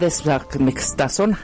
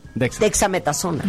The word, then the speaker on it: dexametasona: Imagínate que estén con un paciente y no sepan pronunciar desamectasona. – Dexametasona.